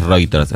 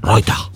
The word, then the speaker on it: Reuters: Pero en realidad no es Reuters, en realidad es Reuters.